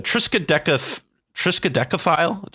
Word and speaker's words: Triskaidekaphile: Next card on our list is what I think should be the new name for the Lab Maniacs is a Triskaidekaphile.